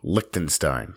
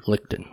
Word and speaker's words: Liechtenstein: Liechtenstein. – Lichten.